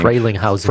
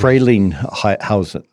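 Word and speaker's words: Frelinghuysen: And then I don't even, how do you say the last name? – Frelinghausen. – Frelinghausen. Boy, that was pretty good. Say it again. – Frelinghausen.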